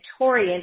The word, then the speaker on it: Torian: It is my first and last name, Ashley Torian, and my name is spelled A-S-H-L-Y. There is no E. A-S-H-L-Y, and then Torian is T as in Tom, O-R-I-A-N.